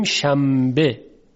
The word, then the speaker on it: شنبه: خب ما میگوییم شنبه، یعنی نون ساکن که قبل از ب قرار گرفته در تلفظ تبدیل میشه به میم، شنبه.